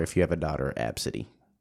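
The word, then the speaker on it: Abcde: A, B, C, D. It's pronounced Absidy.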